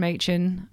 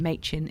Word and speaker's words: Machin: So Catherine Machin. So it's spelled Catherin. And Machin is like machine without the E. So brilliant. And I'm sorry for mispronouncing Machin is making earlier.